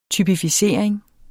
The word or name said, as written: typificering